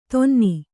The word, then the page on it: ಧೂನನ: dhūnana